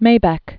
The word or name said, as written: Maybeck